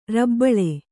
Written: ರಬ್ಬಳೆ

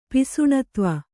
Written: ಪಿಸುಣತ್ವ

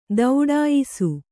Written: ಥಟ್ಟಯಿಸು

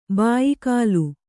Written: ಭಯಾಕುಲೆ

